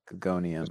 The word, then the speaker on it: Guggenheim: Guggenheim. – That actually sounds pretty good. Pronounced the right way. – I was trying to say it, but I couldn't get the gonium, the onium part.